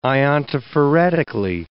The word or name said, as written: iontophoretically